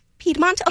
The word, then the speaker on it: Piedmont: How do you say that? – Piedmont? – Piedmont? Okay. – Yes. – I was on the right track.